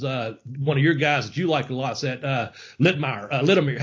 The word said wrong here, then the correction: Latimer: How you say it? Tom? – Yeah. Tom Latimer. – Latimer.